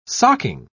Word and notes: socking: British English

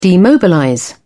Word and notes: demobilize: British English

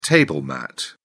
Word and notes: table mat: British English

